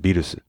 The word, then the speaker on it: Beerus: Well, that's because for one thing, in Japanese, it's pronounced Beerus. – Right. Well, there you go. – But it's also based not on the English pronunciation, which is pretty much non-existent in Japan, but mm-hmm. on the German pronunciation, Virus, which was rendered in Japanese as Beerus.